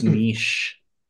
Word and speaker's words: Nietzsche: It's pronounced niche.